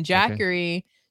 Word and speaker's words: Jackery: So it's Honda, the car company, and then Jackery, Jackery.